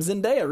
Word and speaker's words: Zendaya: The chat wants you to know that it's Zendaya. – Oh, thank you. I've said it wrong for a long time. Zendaya, really.